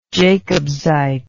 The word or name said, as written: jacobsite